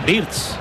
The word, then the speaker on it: Wirtz: Me encanta cómo lo pronuncias, pero creo que lo pronuncias mal. Porque dices Wirtz, es Wirtz.